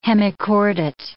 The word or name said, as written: hemichordate